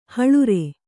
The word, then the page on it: ಹಳುರೆ: haḷure